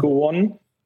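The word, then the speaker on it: Go1: Ich glaube, Go One werden sie ausgesprochen, ne? Aus Australien. – Ja, genau, ja. Go One.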